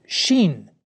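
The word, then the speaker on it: sheen (ש): It's pronounced sheen.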